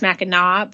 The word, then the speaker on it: Mackinac: It's pronounced Mackinac, but spelled Mackinac.